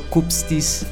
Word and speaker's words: Kupstys: Que él se presente, El Mateo Kupstis Chica.